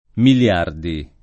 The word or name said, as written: Migliardi